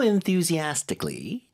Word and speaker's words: enthusiastically: She's been reading it so enthusiastically. 자, 이게 이제 열심히에 해당하는 단어입니다. 발음 좀 도와드릴게요. 워낙 음절이 많으니까. – Enthusiastically. – Enthusiastically. – Yeah. – Enthusiastically. 거기 강세 잘 주세요.